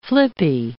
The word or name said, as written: flippy